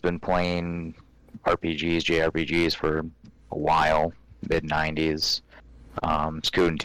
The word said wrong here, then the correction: Suikoden: Suikoden 2. – Oh, okay. – Suikoden. Yeah, sorry. Everybody pronounces it different.